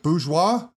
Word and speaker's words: Bourgeois: Bourgeois? – Yeah.